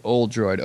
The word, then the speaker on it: Oldroyd: And if you're looking at my podcast, my last name is spelled Oldroyd, Oldroyd, like The Droid, basically like old R2-D2, if you will.